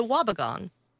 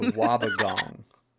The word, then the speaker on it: Wobbegong: The Wobbegong. – The Wobbegong.